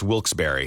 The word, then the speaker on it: Wilkes-Barre: Now, I had heard that it was supposed to be pronounced Wilkes-Barre.